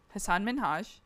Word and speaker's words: Hasan Minhaj: Hasan Minhaj.